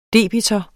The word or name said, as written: debitor